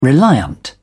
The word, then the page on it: reliant: British English